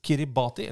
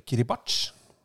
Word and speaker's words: Kiribati: Mens Kiribati, eller Kiribati, som du vel strengt tatt skal uttales, ja vel.